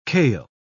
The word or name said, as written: kale